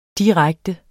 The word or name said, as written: direkte